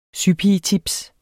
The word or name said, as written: sypigetips